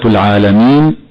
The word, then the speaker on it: العالمين: كما قلنا، كلمة العالمين الألف التي بعد اللام فيها المد بمقدار حركتين فقط وصلا ووقفا، ومن الخطأ أن تمد مدا يخرجها عن المد الطبيعي، فبعضهم يقرأ خطأ هكذا: العالمين.